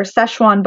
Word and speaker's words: Sichuan: It's called, it's also what an electric daisy is, a ting flower, Sichuan button. It's not spelled like Szechuan sauce, but I think it's pronounced the same.